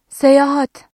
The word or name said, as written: seyahat